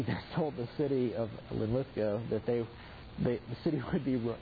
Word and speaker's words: Linlithgow: Linlithgow. Okay, get the English pronunciation of the Scottish town.